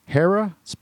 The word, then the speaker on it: Hera: We've got some great business leaders from Hera, pronounced and spelled H-E-R-A, Hera Lighting.